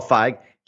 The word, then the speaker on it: Feig: Paul Feig.